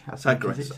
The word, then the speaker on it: Sagres: Please forgive me for saying this wrong. Sangres. Sangres Preta. Sangres Bohemia. – Sagres. – Sagres. Sagres Preta. Sagres Bohemia.